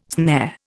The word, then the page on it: snare: Indian